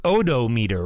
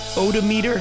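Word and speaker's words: Odometr: I think od- odometer, right, Steve? I, I think that's how it's pronounced.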